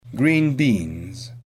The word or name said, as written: green beans